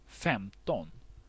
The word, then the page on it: femton: /ˈfɛmˌtɔn/